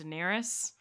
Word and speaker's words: Daenerys: her name is pronounced daenerys Daenerys.